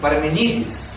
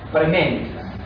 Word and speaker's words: Parmênides: Os gregos dizem Parmenides, mas os portugueses e os europeus dizem Parmênides. É uma... devido ao fato do que, em português, o nome de Parmenides é pronunciado segundo o latim, não segundo o grego.